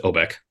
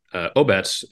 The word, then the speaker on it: Obetz: So Obetz, I chatted with Jonathan earlier, for those who don't know, take off the C and add a TZ. When we decided to name the brewery Obetz, we knew that 99% of the people would pronounce it Obeck and the other 1% would be Czech. And Obetz is the Czech word for community or village.